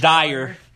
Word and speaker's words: Dyer: Always talk about how people mispronounce my last name, which is Dyer. And they say Dryer. And I always say that it's proof that the world is stupid. Because not only do they mispronounce my name, they add letters. There's no R. The R is at the end. So there's no way it could be Dryer.